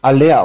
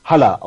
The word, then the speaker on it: Haller: Und ich liebe es, wie er Aller ausgesprochen hat. – Ich Aller. das ganz großartig. – Zumal dem, der, der, Manager Hübner ihn Haller ausgesprochen hat. Hatte auch sowas, hat, nee, hat, hat ja was, ist ja ne, schön, schön heimatverbunden, finde ich ganz großartig, passt da halt nur nicht.